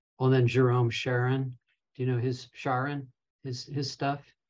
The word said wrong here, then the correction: Charyn: Maybe I'm not pronouncing it. Charyn, Jerome.